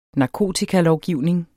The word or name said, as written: narkobagmand